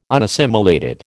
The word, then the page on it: unassimilated: American English